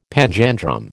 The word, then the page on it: panjandrum: American English